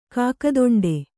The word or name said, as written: ಕಾಕದೊಂಡೆ